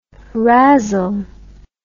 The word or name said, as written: razzle